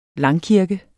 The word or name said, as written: langkirke